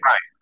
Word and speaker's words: Rian: Is Rian Johnson, is it Ryan? Is that how you say it, or is it Rain? – Ryan. Yeah, he spells it weird, but he says it normal.